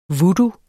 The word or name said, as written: VOODOO